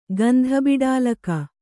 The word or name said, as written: ಗಂಧಬಿಡಾಲಕ